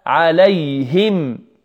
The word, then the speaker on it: عليهم: نقول أنعمت أنعمت عليهم عليهم، لابد من كسر الهاء هنا، عليه هي عليهم.